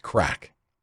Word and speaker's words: craic: The culture can be best described with a Gaelic word that is spelled C-R-A-I-C, pronounced crack.